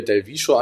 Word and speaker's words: Del Viscio: V-I-S-C-I-O. Das heißt Del, D-E-L-V-I-S-C-I-O.